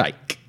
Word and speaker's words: Icke: But one of the main proponent, possibly the main proponent, is David Icke. – Yes. – I-C-K-E. I've been told he pronounces that Icke, not Icky.